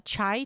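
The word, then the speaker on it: cha (茶): There's a chai tea, which is just a bastardization of the pronunciation of a tea in Mandarin, which is cha.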